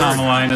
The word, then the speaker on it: Commelina: He knows he said the wrong first letter once he heard the actual word. – Kamaline is a kind of plant. It's spelled C-O-M-M-E-L-I-N-A.